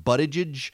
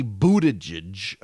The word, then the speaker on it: Buttigieg: And in internal focus groups conducted by Pete Buttigieg, and, you know, I've been saying Buttigieg, but it's apparently Buttigieg.